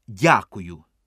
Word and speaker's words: дякую: Замість «спасибі» чи «мерсі» кажіть «дякую». Тільки робіть наголос не на «я», а на «у». «Дякую».